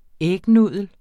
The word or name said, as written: ægnudel